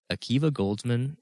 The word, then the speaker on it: Akiva Goldsman: But this episode was directed by Carol Banker and written by, I don't even know how to say this name, but it's Akiva Goldsman.